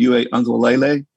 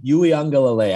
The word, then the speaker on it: Uiagalelei: So I'll try it. Uiagalelei. – I'm close to that. Uiagalelei, I think it is.